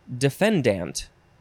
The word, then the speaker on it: defendant: And I guess it says in legal circles, it is often pronounced defendant. I think it's more dant than dent.